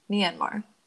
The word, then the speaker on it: Myanmar: It is found in western Thailand and southeast Myanmar, I think is how you say it. Yes. – Myanmar.